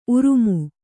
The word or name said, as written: ಊರ್ಮೆ